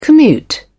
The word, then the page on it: commute: British English